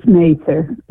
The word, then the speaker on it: Mather: And it's Mather. That's the way to pronounce Mather.